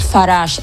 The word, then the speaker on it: Farage: Farage, es que suena tan Farage francés, que es curioso que el hombre sea tan anti-europeísta.